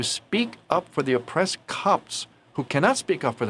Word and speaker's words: Copts: Now, Copts is spelled C-O-P-T-S. It's a little challenging to pronounce those syllables, those consonants, right, all together. Copts. Voice of the Copts.